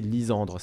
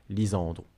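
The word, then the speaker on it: Lisandru: c'est mieux de prononcer Lysandre. C'est, on va dire, la prononciation la plus proche de la prononciation originale, Lisandre.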